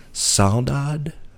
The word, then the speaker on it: saudade: saudade? Are you familiar with this word? – Spell it. – S-A-U-D-A-D-E.